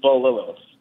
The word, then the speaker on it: Bililies: It's actually pronounced Bolilos.